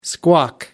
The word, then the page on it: squawk: American English